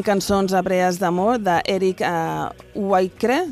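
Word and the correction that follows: Whitacre: No, tu com ho pronunciaries? – A veure, Eric Whittaker. – Whittaker, no? – Està, vale. – No ho sé, té tota sa pinta.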